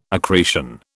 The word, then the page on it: accretion: British English